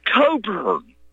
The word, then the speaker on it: Cockburn: And it is Bruce Coburn. Bruce is spelled C-O-C-K-B-U-R-N.